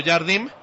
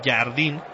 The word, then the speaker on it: Jardim: Y al final, como te decía, loco, plausible lo de Leonardo Jardim. – Uh-huh. – Este sí es Jardim, con M. Se escribe como Jardín, pero sin la N, con una M. No como los que le quieren decir Jardín a este André Jardine.